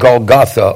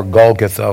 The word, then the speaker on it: Golgotha: So they brought Jesus to the place called Golgotha, or Golgotha, whichever way you want to pronounce it, which means the place of the skull.